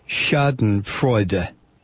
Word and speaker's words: Schadenfreude: It's understandable for English speakers to make your mistake of not pronouncing the ending I in Schadenfreude because of the silent E at the end of so many of our words. But an E at the end of German words following a consonant is almost always pronounced a, as in Porsche, not Porsche.